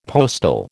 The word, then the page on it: postil: American English